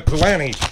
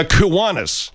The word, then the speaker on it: Kiwanis: It's the Kiwini. Now, see, I forgot. – It's called the Kiwanis. – The Kiwanis.